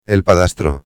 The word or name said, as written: el padrastro